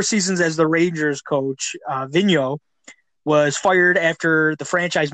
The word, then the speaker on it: Vigneault: Did I say his name right? Is that Vigneault? Yeah, I think you got his last name right.